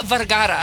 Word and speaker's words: Vergara: Sofia Vergara. – Can you say Vergara? – Roll your R's there, Bob.